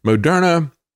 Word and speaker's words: Moderna: Moderna was originally pronounced mode RNA, and then they changed the pronunciation, put the emphasis on a different syllable, and called it Moderna, like we're modern.